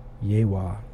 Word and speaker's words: YHWH: That's capital YHWH Nail Gun. Yehwah. That's how that's pronounced.